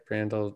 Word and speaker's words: Brandel: Did I say his name right? Brandel? Yeah.